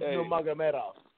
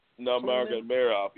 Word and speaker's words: Nurmagomedov: How do you say they got nigger? Not nigger. – Khabib. Say his name, Khabib Nurmagomedov. – Nurmagomedov.